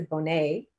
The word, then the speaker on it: Bonet: Yeah, so Jason Momoa and Lisa Bonet, that's how you say your last name, right? – Yeah, Bonet. Lisa Bonet, formerly of The Cosby Show.